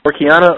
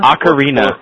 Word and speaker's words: Ocarina: Our closing music tonight is the Zelda 64 Orkiana. I can always mispronounce this. Orkina? – Ocarina.